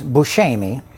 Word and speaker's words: Buscemi: But the correct, Sicilian pronunciation is Buscemi. So when people say Buscemi, that's very close to how I think what the real pronunciation is.